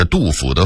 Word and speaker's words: 杜甫: 当然有些纯属俄化的字音我们还应该发正音，比如府和谱两个字，就是杜甫的甫和溥仪的溥这俩字儿。很多人把杜甫的甫念成谱，把溥仪的溥念成甫，这是必须要纠正的，咱不能姑息错字啊。